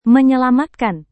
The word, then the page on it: menyelamatkan: Indonesian